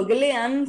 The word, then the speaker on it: Glean: Método Glean, se escribe Glean, pero G-L-E-A-N,